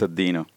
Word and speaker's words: Deno: There is some confusion about the pronunciation, but on the TSConf 2019, Ryan Dahl clarified that the official name was pronounced Deno.